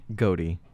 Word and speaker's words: Godey: Gotti? How do you say G-O-D-E-Y. Would that be Gotti? – Goody. – A Goody? – Goody, probably.